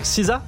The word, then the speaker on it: SZA: Kill Bill version musique, ça donne ce morceau de SZA qu'on vient d'écouter. – SZA. – Juste là. SZA. Ça se prononce SZA? – Ouais, on est d'accord.